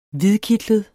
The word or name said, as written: hvidkitlet